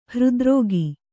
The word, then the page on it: ಹೃದ್ರೋಗಿ: hřdrōgi